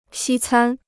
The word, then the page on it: 西餐: xī cān